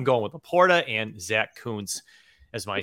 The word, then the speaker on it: Kuntz: If you see Zach Kuntz, it's K-U-N-T-Z and it's pronounced Kuntz. Okay. Don't be yelling any other thing around your house. Okay. Kuntz, K-U-N-T-Z. – Like a double O, Kuntz.